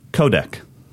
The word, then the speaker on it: codec: Codec. – Codec. All right. C-O-D-E-C. Codec. C-O-D-E-C.